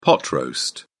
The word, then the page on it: pot roast: British English